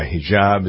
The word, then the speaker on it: hijab: He started yelling hate speech toward a couple of women, one of whom was wearing a hijab.